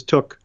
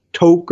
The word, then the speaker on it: Took: The gentleman pronounced it as Took. But I agree. I thought it was Toke or whatever else.